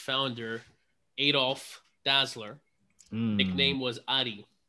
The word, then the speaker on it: Dassler: So to my thinking is it's not Dussler, it's Dassler.